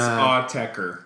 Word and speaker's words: Autechre: is Autechre. – It's Autechre.